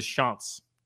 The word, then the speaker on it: Chants: The nickname for Coastal Carolina is the Chants, which is spelled like chants.